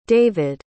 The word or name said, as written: david